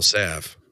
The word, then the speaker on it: salve: I'm still trying to figure out how to spell Salve. That's what I want to. – S-A-L-V-E. – Oh, that's right. The L's in there. Okay. – The L is silent.